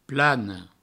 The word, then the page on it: plana: plano